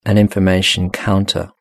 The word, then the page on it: an information counter: British English